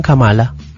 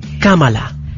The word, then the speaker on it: Kamala: Por favor, no le digan Kamala. Por el amor de Dios, no le digan Kamala. – Kamala. – Es Kamala. Su nombre es Kamala. – En la primera A, porque uno puede decir el acento en la A.